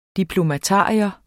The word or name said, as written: diplomatarium